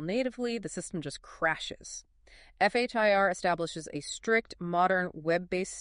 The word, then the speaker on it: FHIR: FHIR, as in building a campfire.